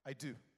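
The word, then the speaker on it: eido: I do. Jesus says, my sheep know. So say, I do know his voice. It's the Greek word I do.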